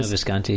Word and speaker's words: Visconti: Pronounced Ludwig, that's what he put in there, directed by Visconti, is that how you say it? – Yeah, Luciano Visconti, yeah.